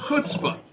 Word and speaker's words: chutzpah: According to Hebrew for Christians, it is pronounced chutzpah.